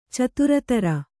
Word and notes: ಚಿತ್ರೋತ್ತರ: citrōttara

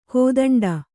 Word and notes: ಕೈದಂಡ: kaidaṇḍa

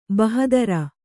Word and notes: ಬಹಾದುರ್: bahādur

